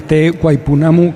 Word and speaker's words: Te Waipounamu: en busca de rincones muy salvajes y de nombres impronunciables como Waipounamu, que no sé si lo he dicho bien, que es una región perdida en la isla sur de Nueva Zelanda que vamos a encontrar en este sitio. – Pues mira, Te Waipounamu, que es el nombre local en maorí